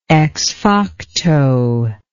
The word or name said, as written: ex facto